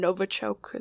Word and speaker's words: Novichok: And then these two individuals that suspiciously came in contact with the same nerve agents that targeted, you know, those two Russian Novichok. Is that the way we say it? – Yeah.